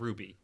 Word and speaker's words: RWBY: I made a mental note, like, tell Stephen how to pronounce Ruby. And I completely forgot. Yeah, RWBY, mm-hmm. Ruby.